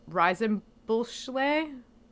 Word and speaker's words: Reisenbüchler: So this movie is created by Sándor Reisenbüchler. Oh, God. – Yeah, that was correct. – Hungarian names. That's exactly how you say it.